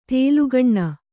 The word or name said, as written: ತೇಲುಗಣ್ಣು